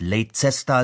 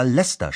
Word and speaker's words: Leicester: Das ist ungefähr so, als würde jemand nach England kommen und uns erzählen, von heute an sollten wir statt Leicester Leicester schreiben und das auch noch gut finden.